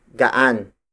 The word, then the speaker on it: gaan: For lightweight objects, we use the root word gaan. Gaan. Gaan. Gaan is spelled as G-A-A-N.